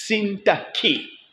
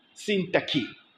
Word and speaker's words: Syntyche: But when I looked up the meaning, it's Sintaki. Sintaki. That's a pronunciation of that other name.